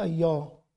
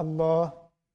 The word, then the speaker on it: الله: أخت تسأل صديقتي لدغة وهي بتكلمني قالت كلمة الله فنطقتها أيّا فأنا نطقتها زيها من باب الهزار أنا فقط كنت أمزح لا يجوز لك ذلك. الأخت اللي بتقلد الأخت اللدغة لا يجوز حتى وإن كان بدعابة. لماذا؟ لفظ الجلالة لابد له تعظيم هي تنطق كذلك أن لسانها لا يستطيع النطق إلا كذلك، فإحنا ما نكررش ما تقول، لأن ده نوع من أنواع سوء الأدب مع لفظ الجلالة. فنقول لها الله بس، لكن ما نقولش إيه على نفس. ثم ربما